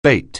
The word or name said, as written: bait